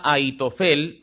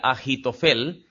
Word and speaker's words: Ahitofel: Este hombre se llamaba Ahitofel, o si pronunciamos la H como J, sonaría Ahitofel.